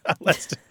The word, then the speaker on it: Leicester: So a word that's spelled L-E-I-C-E-S-T-E-R did used to be pronounced something more like Leicester. Leicester. – Yeah. – But there were three syllables, and now it tends to be two, okay? Because the two syllables that sound roughly the same, the E-I-C and the E-S-T, combine to make one sound.